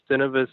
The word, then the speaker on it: Synovus: The last piece of the puzzle for Bass Pro Cabela's is hinging on a deal right now with Synovus. I think that's how you pronounce it.